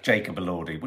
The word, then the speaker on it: Jacob Elordi: Let's have a chat about Jacob Elordi.